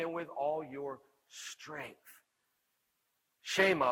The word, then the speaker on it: Shema: And so Jesus quoted something called the Shema. It's spelled S-H-E-M-A in Hebrew, and everybody knew that word.